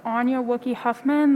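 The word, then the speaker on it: Anja Wookey-Huffman: My name is Anya Wookie Huffman. That's spelled A-N-J-A-W-O-O-K-E-Y hyphen H-U-F-F-M-A-N, and I am testifying on my own behalf.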